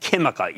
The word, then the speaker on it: Química: That's called Sociedad Química, even though it's spelled Q U I M I C A, which is a big Chilean materials company with significant lithium exposure.